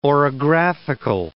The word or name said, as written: orographical